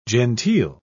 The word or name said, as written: genteel